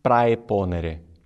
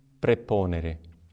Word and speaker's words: praeponere: A pronúncia reconstituída, que seria aquela de Cícero, é Cristo nihil praeponere. Mas não é essa a nossa pronúncia. A pronúncia correta, romana, é Cristo nil preponere.